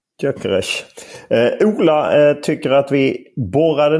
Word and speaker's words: Gyökeres: Hur uttalar du honom? – Gyökeres. – Ja, det är bra. Det sitter. Ja.